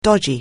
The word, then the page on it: dodgy: anglais